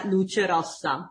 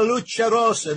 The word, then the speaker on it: Luce Rossa: Our after-hours show, our first after-hours show, Lucha Italiana, go ahead, tell me how you say it. – That's why you need me. Radio Italiana Luce Rossa. – Radio Italiana Luce Rossa.